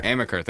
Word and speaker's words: Amaker: The Harvard University coach, Tommy Aikman. I don't want to say it wrong. – Amaker, thank you.